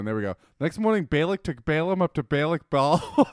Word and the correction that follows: Bamoth Baal: Bamoth Baal.